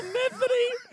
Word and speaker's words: Metheny: Crystal Metheny? What the fuck? – I'm quite sure that it's supposed to be pronounced Metheny.